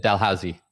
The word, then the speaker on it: Dalhousie: At Dalhousie.